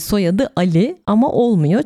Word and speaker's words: Ali: Bu arada Ali değil Ali diye telaffuz ediliyor.